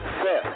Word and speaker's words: Seth: But my name is Seth, kind of like Beth. Seth. Very few people say it properly.